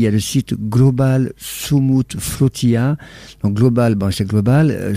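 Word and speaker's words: Sumud: Soumoud, ça veut dire résister, endurer, persévérer, espérer, etc.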